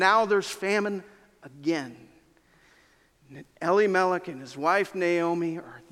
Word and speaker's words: Elimelech: Gavin pronounced it different than I learned, so I'm trying to retell myself. Elimelech. Naomi and Elimelech face famine, and they leave the land.